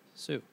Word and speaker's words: Sioux: Is this spelled S-I-O-U-X? – Sox. – Sue. Okay. – And he wins, but the guy's like, I'm not paying you fucking rent.